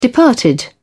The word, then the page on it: departed: British English